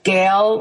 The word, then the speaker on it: Gale: But if you Google my name, which is Gale, like it's spelled like the big storm wind, Gale,